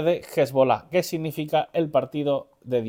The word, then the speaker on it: Hezbollah: Eh, sí, viene de la palabra Hezbollah, que es partido, partido político, y Allah, que es partido de Dios. Entonces sí, viene de ahí, Hezbollah sería la pronunciación correcta.